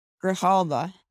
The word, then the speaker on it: Grijalva: Wow, you really butchered that. – Isn't it Grijalva? – Grijalva. – Grijalva. Sorry, Raul Grijalva.